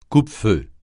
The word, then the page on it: coupe-feu: French